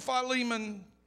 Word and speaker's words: Philemon: The book of Philemon, verse 9. I know some of y'all look at me like, that's not how it's pronounced. That's exactly how it's enunciated.